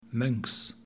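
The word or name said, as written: Mönks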